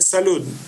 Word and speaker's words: salud: No, it's like salud, like as you say, it's with D at the end, and it's what we say to make any type of toast or cheers.